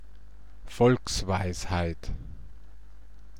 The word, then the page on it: Volksweisheit: /ˈfɔlksˌvaɪ̯shaɪ̯t/